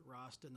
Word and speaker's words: Roston: My name is Howard Rostin. The last name is spelled R-O-S-T-O-N.